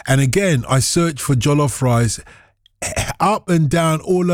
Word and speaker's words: jollof: And jollof, the only thing it rhymes with is Wolof.